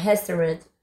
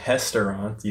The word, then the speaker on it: restaurant: Whenever you pronounce a word that starts with R, remember to do the R sound. So you don't say restaurant, you say restaurant.